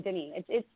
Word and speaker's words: Denis: Well, what do you mean, Denis? It's French. The S is silent, okay?